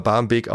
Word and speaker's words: Barmbek: Und ich habe letztens erst gelernt, dass der früher mit CK geschrieben worden ist. Aber schon immer Barmbek ausgesprochen worden ist. Das C ist nämlich ein Dehnungs-C gewesen.